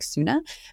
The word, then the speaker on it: Sune: And then for dinner last night, I went to this fish shop called Suni. I don't actually think that's how you pronounce it. I think it's better to pronounce like Suna.